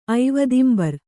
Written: ಐವದಿಂಬರ್

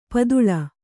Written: ಪಾತಾಳಿ

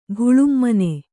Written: ಘುಳುಮ್ಮನೆ

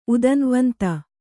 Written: ಉದನ್ವ್ವಂತ

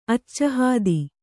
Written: ಅಚ್ಚಹಾದಿ

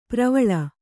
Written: ಪರಿವೇಲ

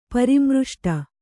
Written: ಪರಾಮೃಷ್ಟ

